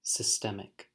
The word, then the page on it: systemic: American English